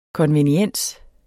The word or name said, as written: konveniens